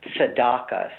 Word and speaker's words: tzedakah: So in Hebrew, in a Jewish context, the word for justice is a word that is pronounced tzedakah. So you might think of it as being written as in English as T-Z-E-D-A-K-A-H, tzedakah.